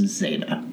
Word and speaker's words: Zeta: I think it's pronounced Zeta. – Yeah, like alpha, beta, gamma, delta.